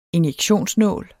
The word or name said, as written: injektionsnål